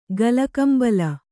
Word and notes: ಗಲಕಂಬಲ: gala kambala

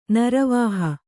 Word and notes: ನರವಾಹ: naravāha

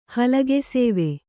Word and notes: ಹಲಗೆಸೇವೆ: Kannada

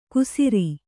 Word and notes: ಕುಸಿರಿ: kusiri

